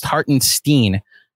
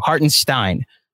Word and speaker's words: Hartenstein: Also, according to Jaden, it's pronounced Hartenstein. – Okay. – Hartenstein. I even read it wrong. Hartenstein. – That's what I thought it was. – So we'll go Hartenstein until he corrects us going forward.